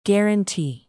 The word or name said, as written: guarantee